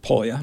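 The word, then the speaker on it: Pólya: So Dr. William Dunham, my guest this evening on Lehigh Valley Discourse, was George, now help me with the pronunciation, Polya, mm-hmm.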